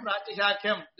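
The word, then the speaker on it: Pratishakhyam (प्रातिशाख्यम्): Pratishakhe bhavam pratishakhyam. This is the way the word is formed. Pratishakhyam is what somebody who undergoes a MA course in Veda should pronounce it as and should know what it actually signifies.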